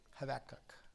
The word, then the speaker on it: Habakkuk: Is that how you pronounce it? – Habakkuk. – Habakkuk. Sorry. Habakkuk.